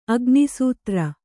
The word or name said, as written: ಅಗ್ನಿಸೂತ್ರ